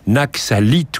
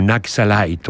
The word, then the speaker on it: Naxalite: Insécurité, cause d'insécurité essentielle, principale dans le pays, il s'agit de la guérilla Naxalite ou Naxalite, on prononcera alternativement, indifféremment, ce mot selon l'accent anglais ou français.